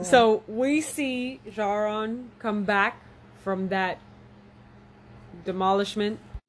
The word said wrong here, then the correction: Jorah: Sorry, I was saying his name wrong. It's Jorah Mormont.